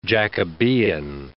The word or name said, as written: Jacobean